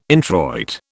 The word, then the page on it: introit: British English